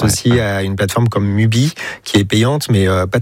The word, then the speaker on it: Mubi: Mubi, tout simplement.